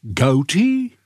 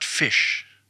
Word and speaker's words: ghoti: Dat spellen we als G-H-O-T-I. Mm-hmm. Hoe zou u dat in het Engels uitspreken? – Goatee? – Ja. Nou, daar staat fish.